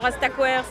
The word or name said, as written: Rastakwer